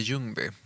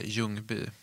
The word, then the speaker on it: Ljungby: De är i Småland, framförallt i Ljungby. Eller Ljungby, som du uttalade, tror jag.